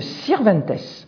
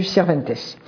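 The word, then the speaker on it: Sirventès: À travers un mode poétique tout à fait particulier qu'on appelle le Sirventes. Ça s'écrit S-I-R-V-E-N-T-E, accent grave, S.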